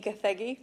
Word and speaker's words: Gathegi: I'm here at CineQuest with Tayler Buck and Eddie Gathegi. Did I say that right? – Oh, wow. Yeah.